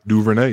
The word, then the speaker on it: Duvernay: This is how I project Devin Duvernay if he gets on the right team. Am I saying his name right, by the way? – Yeah. – Yep. – Yeah. Duvernay, yep.